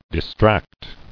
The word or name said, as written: Distractible